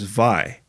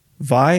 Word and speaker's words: wai: Your featured Hawaiian word is vai, which is water. Vai is spelled W A I. In traditional Hawaiian, W's are pronounced as V's.